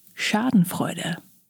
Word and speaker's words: Schadenfreude: Schadenfreude. I'll have my wife, tell me how to say it better. She's German. – Hi,it's pronounced Schadenfreude.